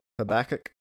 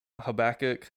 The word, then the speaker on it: Habakkuk: Habakkuk. – Habakkuk.